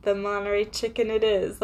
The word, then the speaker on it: Monterey: It's called Monterey chicken. People would pronounce it monetary chicken.